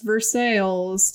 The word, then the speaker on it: Versailles: And in Ohio, we have a city called, it's spelled like Versailles, but it's pronounced Versailles to the locals.